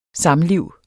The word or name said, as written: samliv